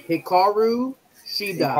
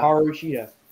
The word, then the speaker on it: Hikaru Shida: Hikaru Shida. – Hikaru Shida.